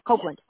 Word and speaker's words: Copeland: Yeah, Copeland.